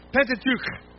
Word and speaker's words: Pentateuch: Yeah, and if you said it right, you should have spit on the person in front of you. Pentateuch.